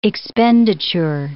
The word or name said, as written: expenditure